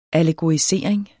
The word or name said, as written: allegorisering